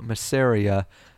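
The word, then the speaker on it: Masseria: So that same year in 1920, Lower Manhattan gang boss Joe Massaria, did I say that right? Massaria? – I believe so.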